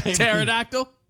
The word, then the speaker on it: pterodactyl: Pterodactyl? – Yeah. – You can't hear him go to the bathroom because the P is silent? – Or like gnat.